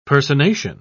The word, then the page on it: personation: British English